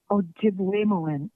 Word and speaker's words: Ojibwemowin: And you have a daughter who also is fluent in, am I pronouncing this correctly, Ojibwemowin? – Ojibwemowin.